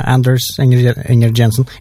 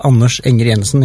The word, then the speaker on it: Anders Enger Jensen: Yeah, Anders Enger Jensen. It's called Anders Enger Jensen in Norwegian, but... – Sorry, say that again slowly. – Anders, that's how people say it, or Anders, but Anders is okay.